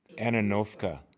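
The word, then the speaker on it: Antonovka: Help me pronounce it, Anavasta. It's a Russian rootstock. – Ananovka.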